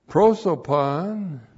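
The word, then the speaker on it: prosopon (πρόσωπον): Prosopon looks like that in the Greek, P-R-O-S, long O-P-O-N, prosopon.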